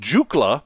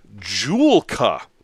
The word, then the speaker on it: Joolca: I kept calling it Jukla. That's an entirely different company that has nothing to do with toilets. The name of the company is Joolca. J-O-O-L-C-A.